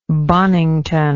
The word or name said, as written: bonington